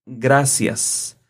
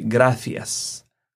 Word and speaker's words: gracias: Because in Spain, they pronounce different the letter C. Mm-hmm. So if you go to Latin America, you will listen to the people saying gracias. But in Spain, they will say gracias, gracias.